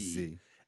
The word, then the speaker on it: Sze: S-Z-E. – S-Z-E. S-Z. I'm going to say the S is silent. It's just Z.